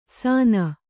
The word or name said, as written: ಸಾನ